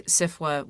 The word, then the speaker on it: SFWA: And the phrase that I've been using is that we want SIFWA, which is how you pronounce SFWA.